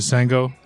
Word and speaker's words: Shango: S-A-N-G-K-O-F-I. – Okay. – It is a mixture of the Yoruba Orisha Shango. – Okay. – But you can pronounce it Sango.